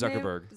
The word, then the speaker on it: Zuckerberg: How do you say his Zuckerberg.